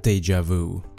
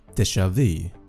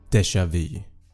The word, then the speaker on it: déjà vu: Innan ni blir helt galna i kommentarsfältet och skriver “Det uttalas faktiskt déjà vu” så vill jag bara säga att ja, på engelska uttalas det déjà vu. Men på franska, som ordet kommer ifrån, så uttalas det déjà vu. Och när jag sökt efter ordet i bland annat Svenska Akademins ordböcker på nätet så säger de déjà vu.